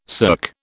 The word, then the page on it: cirque: British English